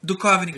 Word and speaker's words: Duchovny: Como é que fala o nome dele? – Duchovny. – Duchovny, cara. – Duchovny? – É, Duchovny. – Duchovny. – Lembra de OVNI, Arquivo X, OVNI, Duchovny.